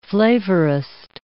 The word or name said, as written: flavorist